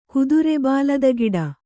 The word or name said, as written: ಕುದುರೆಬಾಲದಗಿಡ